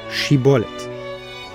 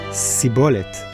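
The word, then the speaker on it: shibolet (שיבולת): Please say, shibolet, the Hebrew word for a stalk of grain. Now, Bnei Ephraim had a characteristic lisp. They couldn't produce the sh sound. So anyone pronouncing shibolet as sibolet was killed on the spot.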